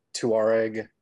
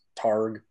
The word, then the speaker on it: Touareg: Well, you can pronounce it Touareg, or, you know, if you're from the American South, Targ.